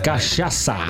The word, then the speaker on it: cachaça: Cachaça. Yes, yeah, Brazilian. – Is that how I pronounce it? – Yeah, so you've got the. There it is there. Chris. Oh, well, here, I mean. – So it's here. Oh, it's like curacao, yes. – C-A-C-H-A-C-A.